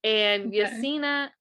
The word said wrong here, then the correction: Yasenia: or no, no, I'm sorry. Yasenia Patino.